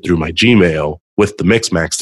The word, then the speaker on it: Mixmax: So that, along with sending out proposals with Mixmax. I never can pronounce that. – I think you got it right.